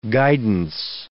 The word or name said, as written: guidance